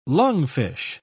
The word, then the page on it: lungfish: British English